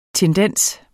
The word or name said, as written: tendens